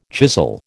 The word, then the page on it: chisel: American English